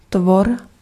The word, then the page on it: être: France (Ouest); [ɛːtʁ]